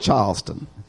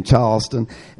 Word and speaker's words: Charleston: I found out that the way that they know that you're not a local, if you pronounce it Charleston, the locals there call it Charleston. They leave out the R and the L. It's Charleston.